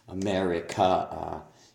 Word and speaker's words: America: America. You put a stress, end up with a stress on the of America, and it should be on the mare, not on the May God grace America.